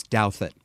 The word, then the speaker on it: Douthat: And that is the pronunciation of his name. I had to look it up earlier. I'd been pronouncing it wrong. It's Douthat.